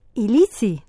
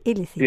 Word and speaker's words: Illici: Se aplica, como decíamos, volvemos al caso que me nombrabas de Elche, Alicante, la polémica basílica esta de Illici, ¿no? ¿Cómo se pronuncia? – Illici. – Illici.